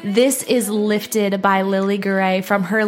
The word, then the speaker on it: Garay: And her last name is spelled G-A-R-A-Y. So that's Lily, like the flower, and then Gray.